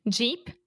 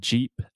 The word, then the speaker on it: jeep: Jeep, sheep. – Yeah. So, sheep, Ovelia, has the sh sound, sh, sheep, and then jeep has the j, j, jeep.